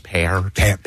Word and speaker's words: Peart: And Peart. Peart. And forever, I called him Pert. I called him Neil Pert. – Everyone did. – Until I heard him say it himself. It's Peart. P-A-R-T.